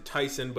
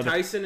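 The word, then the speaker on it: Taisun: I'm assuming you pronounce it Tyson, but it's T-A-I-S-U-N.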